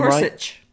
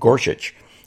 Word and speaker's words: Gorsuch: Did I pronounce his name Gorsuch. – Right? Gorsuch, our newest Supreme Court justice.